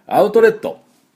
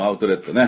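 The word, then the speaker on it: outlet: アウトレットね、東京なんで軽井沢とか、木更津まで行ったことないですけど、この辺で行きますね、安いし、いいものが安いということで、アウトレット、これがまた発音が違います。どんな発音かっていうと、アウトレット、アウトレットっていう音になるんですね。これコツはですね、アウト、これ出さなくていいです。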